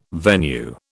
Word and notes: venue: British English

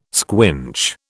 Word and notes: squinch: British English

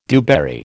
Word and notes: dewberry: American English

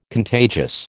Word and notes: contagious: American English

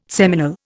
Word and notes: seminal: Indian

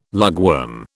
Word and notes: lugworm: British English

